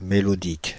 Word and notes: mélodique: [me.lɔ.dik]; Paris

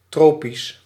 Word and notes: tropical: France (Paris); [tʁɔ.pi.kal]

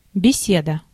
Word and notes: беседа: /bʲɪˈsʲedə/

